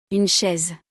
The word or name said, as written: une chaise